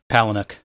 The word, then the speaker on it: Palahniuk: Palahniuk. – It's based on the Chuck Palahniuk book, though a lot of liberties were taken with the structure. – Although I will say that Palahniuk has gone on record and said that the movie is better than the book.